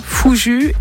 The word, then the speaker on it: Fouju: Est-ce que je prononce bien déjà ? Ça s'écrit F-O-U-J-U, hein, donc Fouju.